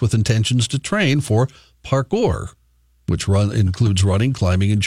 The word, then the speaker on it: parkour: Parkour.